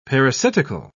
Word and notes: parasitical: British English

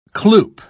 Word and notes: cloop: British English

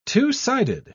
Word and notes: two-sided: British English

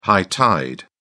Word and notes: high tide: British English

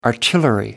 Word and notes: artillery: American English

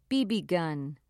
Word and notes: bb gun: American English

